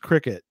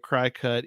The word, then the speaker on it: Cricut: It's probably pronounced Cricut, but to avoid the confusion, I'm trying to say it CryCut, even though that's not the proper pronunciation.